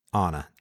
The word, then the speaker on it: Anna: Anna. – She's referred to as Anna, but it's Anna Rudolph. Anna. Yeah, I mean, in that's the Hungarian pronunciation, it's more of an ah, less of an ah.